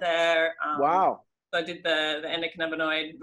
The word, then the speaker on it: endocannabinoid: Endocannabinoid.